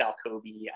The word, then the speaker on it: Alkoby: No one can even really pronounce my last name. It's Alkoby.